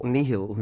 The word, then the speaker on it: nihil: Well, in Latin, nihil. – Nihil. – Means nothing. – Right. – And the pronunciation of the original Latin root is nihil.